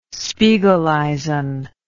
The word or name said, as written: spiegeleisen